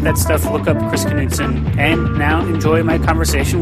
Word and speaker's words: Knutson: like English major says, he keeps telling me it's Knutson. – It's Knutson. The K is silent.